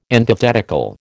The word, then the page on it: antithetical: American English